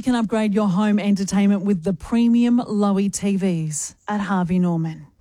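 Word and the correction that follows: Loewe: Lova. – Oh, is it Lova? – See, at the top, how it says Lova? – Oh, yes. – Almost rhymes with sofa? – Yep.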